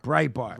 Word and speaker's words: Breitbart: Breitbart? – Breitbart.